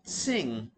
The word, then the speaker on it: Singha: Because Sing is the correct pronunciation of the beer.